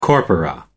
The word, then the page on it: corpora: American English